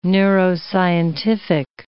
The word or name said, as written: neuroscientific